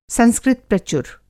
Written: संस्कृतप्रचुर

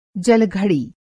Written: जल-घड़ी

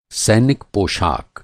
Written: सैनिक

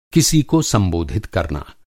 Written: संबोधित

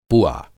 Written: पुआ

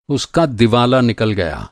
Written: दिवाला

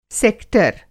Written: सेक्टर

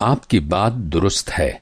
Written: दुरुस्त

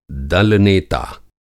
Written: दल-नेता

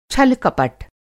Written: छल-कपट

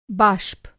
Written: बाष्प

